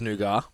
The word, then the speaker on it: nougat: It's nougat. Yeah. T is silent.